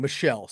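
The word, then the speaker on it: Michel: Yeah, my bad. I said Mitchell. I'm sorry, I read that wrong. Michelle. So we go Ayuk.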